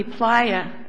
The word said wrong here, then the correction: Plata: supplied in this case, because Brown versus Plata? – Plata, I'm sorry.